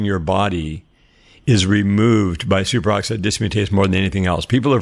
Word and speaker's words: superoxide dismutase: It's called superoxide dismutase. Am I pronouncing that right? – Oh, yeah. Yeah. SOD, superoxide dismutase.